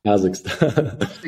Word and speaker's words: Kazakhstan: Kazakhstan.